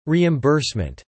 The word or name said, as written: reimbursement